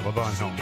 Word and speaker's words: Levon Helm: Levon Helm, is that how you say it? – Uh-huh. – I want to say it the way you say it. – Yeah, Levon Helm.